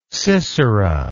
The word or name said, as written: Sisera